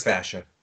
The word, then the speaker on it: fascia: He actually has a torn plantar fascia. I always say that wrong. – Yeah, but it's a tough one. Plantar fascia. – Fascia. Plantar fascia.